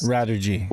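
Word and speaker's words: Radirgy: And while he was doing that, a translator named Wired Crackpot, who is the person responsible for the Radurgy, is that how you pronounce that fan translation on Dreamcast? – Radurgy.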